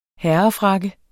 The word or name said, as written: herrefrakke